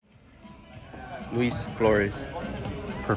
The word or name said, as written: Luis Flores